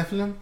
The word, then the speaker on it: Nephilim: Is that how you say it? – Nephilim, yeah. – The Nephilim? – Yeah.